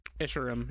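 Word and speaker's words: Escharum: The pilot is captured by Jega, an elite that is a close friend of, how do you pronounce, Esherim? – Esherim, yeah. – Got it.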